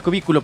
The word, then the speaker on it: cubículo: No, cubí. Lleva acento en la I. – Ah, cubículo, perdóname.